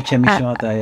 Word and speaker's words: Chemi Shimada: One of the pieces within this expanded film exhibition was the slide projector bass piece with, is it Chemi Shimada? Have I said that right? – That's right, yeah, Chemi Shimada, yeah.